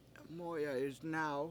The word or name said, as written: MO7ya